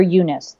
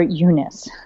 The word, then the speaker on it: Youness: I call it your Eunice. The why, it's spelled, it sounds dirty, I know. – Or it sounds like an old lady name, one or the other. – Right. Well, same, right? Either sounds like something that you'd cover in your bathing suit region or an old lady name. It's spelled Y-O-U-N-E-S-S, the thing that makes you you.